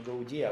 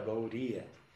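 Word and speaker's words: Gauḍīya: И на самом деле правильно произносить его не «гаудия», а «гаурия». Это такое глубокое «ре», среднее между «ре» и «де», «гаурия».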